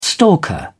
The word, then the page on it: stalker: British English